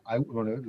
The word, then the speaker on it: IAWN: Uno que se llama el IWN, lo pronuncio en inglés porque como lo pronunciamos, IWN, se escribe IAWN y significa...